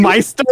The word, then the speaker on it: maester: In the show, it's literally pronounced Meister.